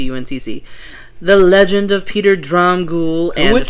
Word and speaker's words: Dromgoole: Peter Dromgoole. Dromgoole. Dromgoole. – Okay. – Unless it's Dromgoole, but there was no... Kind of like Svengoole. There was no mention of any pronunciation there.